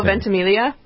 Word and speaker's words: Ventimiglia: The character played by, is it Milo Ventimiglia? How do you pronounce Milo Ventimiglia? – Yeah.